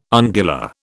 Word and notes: ungula: British English

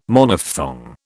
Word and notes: Monophthong: British English

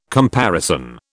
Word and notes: comparison: British English

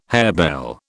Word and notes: harebell: British English

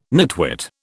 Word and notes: nitwit: British English

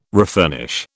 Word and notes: refurnish: British English

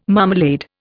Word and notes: marmalade: Indian

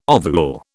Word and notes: ovule: British English

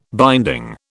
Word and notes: binding: British English

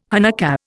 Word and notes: Hanukkah: Indian

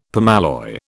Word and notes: permalloy: British English

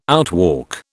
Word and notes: outwalk: British English

